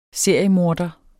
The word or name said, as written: seriemorder